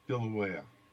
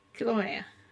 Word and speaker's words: Kilauea: Kilauea. – Kilauea.